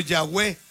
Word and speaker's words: Yahweh: Eran puras consonantes. Son puras consonantes. Le tuvieron que agregar conforme a la historia para poder pronunciar. Pero inclusive era como Yahweh. – Ah, ok. – Yahweh, para que me entiendas, como Yahweh. Para poder pronunciar el nombre, ¿no? De Dios.